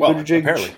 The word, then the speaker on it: Buttigieg: Did you hear Pete Buttigieg? Now I'm starting to sound like one of them. I wish I could find an explanation of why it's a soft G on the last letter. I don't get that, but that's the way those Maltese operate.